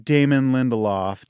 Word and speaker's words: Damon Lindelof: Well, knowing Damon Lindelof, did I pronounce his name right? You did.